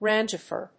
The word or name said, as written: rangifer